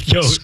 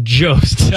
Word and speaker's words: Jost: Soft J? Jost? Jost? – Go with Jost. – Scarlett Johansson. – No. – Johansson? – It's very much Jost.